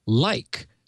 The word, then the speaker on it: Leik: And finally, I got a nice note from Jeremy Like, spelled L-E-I-K, and he wrote, pronounced like, blame my German ancestors, in Diamondale, or Demondale, Michigan.